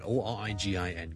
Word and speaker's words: origin: Number two 咧，如果读成 origin 咧，就会怪怪地啦，因为个重音其实 origin O R I G I N 个来源咧，我哋个重音系第一个音节嘅，所以 single law origin origin。